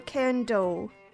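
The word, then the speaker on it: Cairndow: And the area, is it Cairn Dow or Cairn Doe? – Just Cairn Do.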